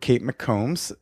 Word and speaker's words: Kate McCombs: We're here with Kate McCombs.